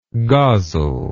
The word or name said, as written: gozzle